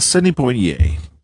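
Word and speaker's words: Sidney Poitier: Sydney Poitier.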